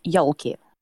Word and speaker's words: Ёлки: In Russia, we also pronounce it Ёлки. Ёлки.